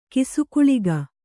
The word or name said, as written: ಕಿಸುಗುಳಿಗ